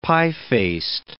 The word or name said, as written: pie-faced